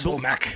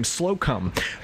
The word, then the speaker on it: Slocum: It's like Slomek. – I think it's... – Slomek? – It looks like Slocum. – Slocum? – It's S-L-O-C-U-M.